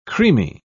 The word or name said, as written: creamy